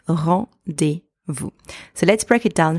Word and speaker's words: rendez-vous: And finally, the letter S at the end of that word is completely silent. So, if we are putting all of these sounds together, we now have rendez-vous.